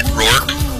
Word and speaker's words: Rourke: Rourke. Rock.